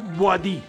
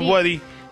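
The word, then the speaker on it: Bouaddi: Del día, usted que es el de los idiomas, dígame, porque he escuchado diferentes maneras en que se pronuncia Ayub. O Ayub, el 6 de Marruecos, Baudí. – Ayub. – ¿Cómo? – Ayub. – Ayub Boadí. – Ayub. – Baudí. Ayub Boadí.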